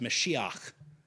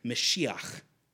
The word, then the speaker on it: Mashiach: Or if you speak to somebody who has a Jewish background, it would be pronounced Mashiach, right? You got to got to kind of clear your throat on that last syllable there. But Mashiach.